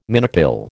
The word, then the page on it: minipill: American English